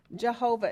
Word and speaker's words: Jehovah: Let's start here with the word Lord in capital letters, and that word is the word Jehovah, or Yahovah, Y-A-H-O-V-A-H. Jehovah is how we pronounce it, J-E-H-O-V-A-H, all capital letters.